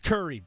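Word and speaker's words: Kure: It's Curry Beach.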